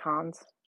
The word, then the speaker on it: Cannes: And that's the Cannes, right? That's how you pronounce it, Cannes? – Yeah, I think so.